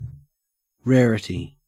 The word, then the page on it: rarity: Australian English